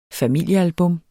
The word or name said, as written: familiealbum